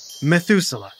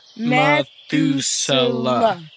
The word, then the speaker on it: Methuselah: Methuselah. – Methuselah.